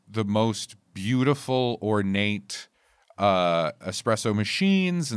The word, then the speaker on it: espresso: It's espresso. That's how they say it. – Oh, God. It's egg-spresso.